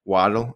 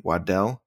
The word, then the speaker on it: Waddle: and that's Jalen Waddle, or as I heard it pronounced the other day, Waddell.